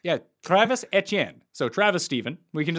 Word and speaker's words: Etienne: Now, it's no, because there shouldn't be. There shouldn't be a third syllable. There shouldn't be. It should just be Etienne if you're actually doing it right.